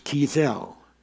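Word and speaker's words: Keysil: Kiesel, pronounced Kiesel, K-E-Y hyphen S-I-L,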